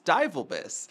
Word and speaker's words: Divelbiss: And I realized fairly quickly, and I don't know why this didn't click sooner, but it was like, nobody can pronounce Divelbiss. – Right. – You know, Divelbiss, you know, and every judge is struggling with this.